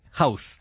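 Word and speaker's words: Houzz: House.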